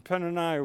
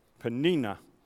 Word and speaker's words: Peninnah: And Peninnah would just rub it in. I didn't say that right. I got to look at my Peninnah. Okay, Peninnah.